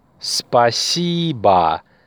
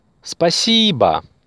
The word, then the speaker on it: Спасибо: Спасибо. Спасибо. The stress is on the second syllable. Спасибо.